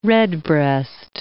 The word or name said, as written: redbreast